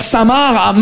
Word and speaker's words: Saint-Marc: On est d'accord, les Français, que Saint-Marc, ça s'écrit M-A-R-C, comme le prénom Marc. Mais vous, vous mettez un saint devant le prénom Marc, le C, il est parti en grève.